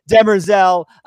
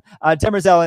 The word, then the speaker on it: Demerzel: So, and Nikki, you brought up Day's order to Demerzel. I was so close to saying it right this time. Demerzel and assassination of Halima.